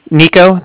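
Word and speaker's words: Niko: Nico, that's N-I-K-O.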